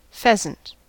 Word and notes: pheasant: American English

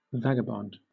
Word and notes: vagabond: British English

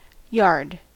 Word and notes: yard: American English

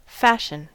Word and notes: fashion: American English

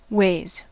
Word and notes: ways: American English

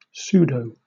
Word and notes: pseudo: British English

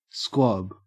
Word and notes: squab: Australian English

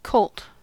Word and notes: colt: American English

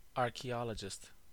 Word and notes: archaeologist: American English

